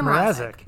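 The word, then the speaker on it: Mrázek: Peter Mrazek. – Mrazek, there's no N in his name. I'm adding N's for no reason.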